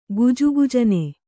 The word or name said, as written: ಗುಜುಗುಜನೆ